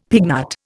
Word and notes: pignut: Indian

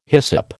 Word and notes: hyssop: American English

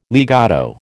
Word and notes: legato: American English

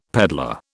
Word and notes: pedlar: British English

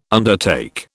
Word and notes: undertake: British English

